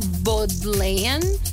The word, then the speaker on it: Bodleian: Bodleian.